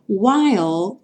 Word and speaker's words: While: While vai ter a finalização igual do little, ou seja, com L no céu da boca e o E não pronunciado.